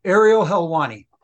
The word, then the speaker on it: Helwani: You know, somebody else that had a great career was The Undertaker, and he actually had made an appearance on the Aerial Hawaii show. Excuse me, Hawaii. I can't pronounce it, I guess. We'll take two. – Okay, Aerial Helwani. – Aerial Helwani. There you go.